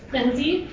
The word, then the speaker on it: Lindsay: I'm Lindsay Caruso, general counsel for Haven Health. – I'm sorry, what is your name again? – Lindsay Caruso. – Could you spell it, please? – Sure. L-I-N-D-S-A-Y. Last name F-R-A-G-O-S-O.